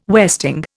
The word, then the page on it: westing: Indian